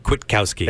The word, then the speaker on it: Kwiatkowski: If Gary Patterson and Pete Kwiatkowski. I'm sorry. – Let me get that for you. Kwiatkowski. – Thank you. I was butchering that. – Kwiatkowski.